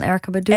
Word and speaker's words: Erykah Badu: En jij hebt een nummer meegenomen, In Love With You, van de zangeres Erykah Badu. Zeg ik dat goed? – Ja, van Erykah Badu.